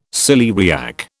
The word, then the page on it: celeriac: British English